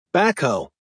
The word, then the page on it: backhoe: American English